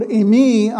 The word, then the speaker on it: eimi: Instead of the simple word eimi, I am, he would add the pronoun ego, ego eimi, I am. The stress is on the I.